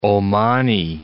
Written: Omani

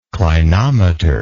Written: clinometry

